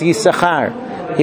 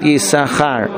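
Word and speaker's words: Yissachar: So Yisachar, we ordinarily read it as Yisachar, even though it's spelled with two shins, Yisachar. We read it Yisachar.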